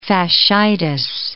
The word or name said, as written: fascitis